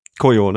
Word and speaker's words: Koyo: Und als äh, drittes, äh, wie, wie spricht man die aus? Koyo, Kojo? Koyo. Koyo, ne?